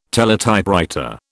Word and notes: teletypewriter: British English

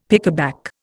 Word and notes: pickaback: Indian

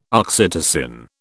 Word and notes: oxytocin: British English

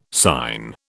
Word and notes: sign: British English

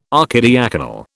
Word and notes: archidiaconal: British English

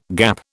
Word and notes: gap: British English

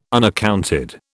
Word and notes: unaccounted: British English

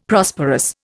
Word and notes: Prosperous: Indian

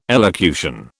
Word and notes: elocution: British English

